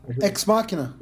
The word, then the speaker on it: Ex Machina: Ex Machina.